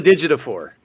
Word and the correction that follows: Ejiofor: Yeah. Chitwell. I think that's how you say his name. – How you got that last name with Ejiofor.